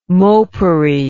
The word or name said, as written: mopery